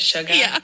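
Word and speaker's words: Suga: Sugar.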